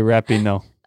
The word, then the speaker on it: Rapinoe: Rapinoe, no sé cómo se pronuncia. – Sí, Rapinoe.